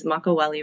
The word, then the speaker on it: Makaweli: So I get most of all of my beef from Kauai, from the leeward side, which is Makaweli Ranch, and they pronounce the W. That's the Niihau dialect.